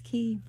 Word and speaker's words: Quay: How are you going to do Q-U-A-Y and say Key? – It's Key.